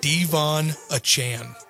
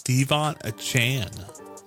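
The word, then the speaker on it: De'Von Achane: He said, well, now that I've done that, I'm going to ask the announcers to pronounce my name properly. Devon Achane. – Devon Achane. – His name is pronounced Devon Achane.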